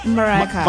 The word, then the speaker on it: Mariah Carey: Mariah Carey.